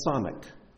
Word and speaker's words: Samech: Now the next letter in the alphabet is the letter Samek. The letter Samek. Looks like that. And it's spelled S-A-M-E-C-H. Samek.